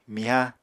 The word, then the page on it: miha: Apatani